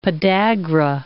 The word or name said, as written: podagra